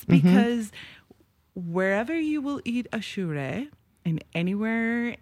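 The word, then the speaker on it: Aşure: So it is called achure.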